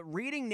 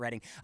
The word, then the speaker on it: Reading: Yeah, it's spelled Reading. I guess they pronounce it Reading.